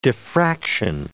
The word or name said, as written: diffraction